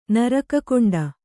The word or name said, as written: ನರಕಕುಂಡ